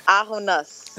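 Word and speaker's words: Ah W Noss: Ahonos. Did I say that right? Ahunus. – Ahunus.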